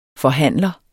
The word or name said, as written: FORHANDLERNES